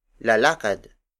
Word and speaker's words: lalakad: Another example is lalakad. Lalakad. Lalakad. Lalakad is spelled as L-A-L-A-K-A-D.